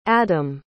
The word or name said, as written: adymn